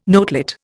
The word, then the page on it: notelet: Indian